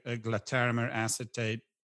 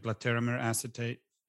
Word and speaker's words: glatiramer acetate: the glatiramer acetate, am I saying that right? Is that glatiramer acetate? – Glatiramer yeah. acetate.